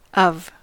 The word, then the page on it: of: /ɒv/; /ʌv/; /əv/